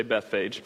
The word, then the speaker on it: Bethphage: Now when they drew near to Jerusalem, they being Jesus and his disciples, his followers, and came to Bethphage, there's a Hebrew way to pronounce that that you don't care about, but we mostly butcher it, but we'll say Bethphage,